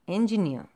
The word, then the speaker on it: engineer: See, so the stress is on the last ear. The R is silent. You have the U sound there. So engineer. So the stress is on the last syllable.